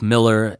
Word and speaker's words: Millar: That's Mark Miller, M-I-L-L-A-R. He's Scottish. He says it even though it's, most people know it's Millar, it's actually pronounced Miller.